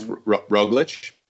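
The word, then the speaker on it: Roglič: I think it's Roglic.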